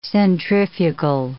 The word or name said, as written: centrifugal